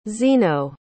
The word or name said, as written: zeno